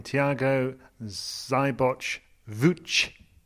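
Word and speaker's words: Thiago Seyboth Wild: Until 24 hours ago, but we've all done some heavy listening to his voice note on the ATP Media bio, and we really, really are trying to get his name right, and we hear it as Thiago Szybacz-Wilcz. You've been saying it on radio all day. – I was told to call him Thiago Szybacz-Wilcz.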